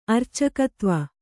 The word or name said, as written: ಅರ್ಚಕತ್ವ